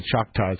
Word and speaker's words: Choctaws: Those coalitions backed a lawsuit filed in 2002 that succeeded in shutting down two Texas casinos that posed competition to the Choctaws.